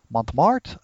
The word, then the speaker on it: Montmartre: I don't know how you say it. Montmartre? – That sounds good.